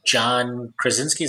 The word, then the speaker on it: Krasinski: so I was listening to John Krasinski. Is that how you pronounce his name? – Yep.